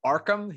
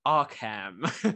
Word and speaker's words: Arkham: And I love the way that he pronounced Arkham. He says Arkham. he pronounces it just like you would think that a proper Englishman would. He calls it Arkham instead of Arkham.